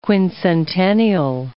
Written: quincentennial

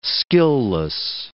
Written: skilless

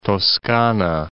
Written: Toscana

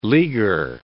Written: leaguer

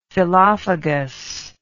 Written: phyllophagous

